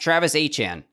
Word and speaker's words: Etienne: Travis Etienne came out with the Saints and corrected all of us to say that his name is actually pronounced Travis Etienne.